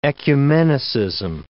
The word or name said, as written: ecumenicism